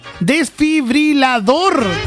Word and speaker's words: desfibrilador: Desfibrilador. Desfibrilador. Esa palabra... No me puedo acordar cómo se dice... Dije, ¿cómo fue que dije yo? Desfibrilizador, algo así. Y la palabra correcta es desfibrilador.